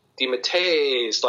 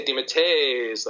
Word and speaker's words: DeMatteis: and they pronounce it DeMatteis, like one, there's no break with the is, you know what I mean? Mm-hmm. They say DeMatteis, like one long mm-hmm. sound.